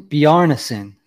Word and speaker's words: Bjarnason: I believe in the second round they took Carson Bjornsson. Let me see, make sure I'm saying his name correctly there. Carson Bjarnason, sorry about that, was their second-round pick, their third overall pick.